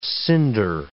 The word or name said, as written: cinder